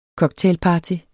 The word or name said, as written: cocktailparty